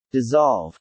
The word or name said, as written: dissolve